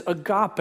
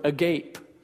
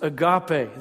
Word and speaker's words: agape: It is agape. It looks like the word agape, pronounced agape.